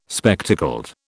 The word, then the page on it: spectacled: British English